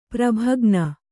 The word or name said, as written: ಪ್ರಭಗ್ನ